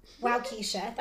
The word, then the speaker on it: Waukesha: Waukesha, Wisconsin. Waukesha.